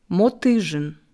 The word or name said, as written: Motyzhyn